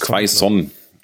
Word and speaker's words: Quaison: Mal hier vorne muss halt noch ein bisschen mehr kommen von so Mateta, Quaison, der schon, oder Quaison, wie spricht man ihn eigentlich? – Quaison. – Quaison. – Ja, wie man es liest quasi.